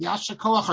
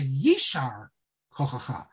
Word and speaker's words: Yishar Kochacha: And the proper way to pronounce it is not Yashakoach or Yashar Kochacha. Certainly it's not Koach, but it's not Yashar Kochacha. What it is a Yishar Kochacha.